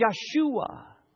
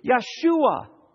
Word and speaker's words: Yeshua: It is Yeshua. That's how you say it in Hebrew. Yeshua.